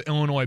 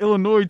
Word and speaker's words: Illinois: I was gonna say, you mispronounced Illinois, too. – What do you mean I mispronounced Illinois? – What Travis said. You put an S at the end, definitely. I think you missed the front end, too.